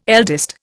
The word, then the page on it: eldest: Indian